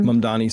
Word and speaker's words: Mamdani: Mamdani, is that how you say his name? – Mamdani.